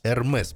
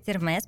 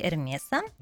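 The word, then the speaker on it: Hermès: А, правильно говорить Эрмес, потому что это фамилия основателя Терри Эрмеса. А, и если бы это просто было э, слово французское, тогда да, без окончания бы. – Но так как это фамилия, и плюс у него, плюс у него есть еще там другие корни, не французские, поэтому произносится Эрмес.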